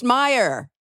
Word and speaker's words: Mayer: They're both pronounced Meyer.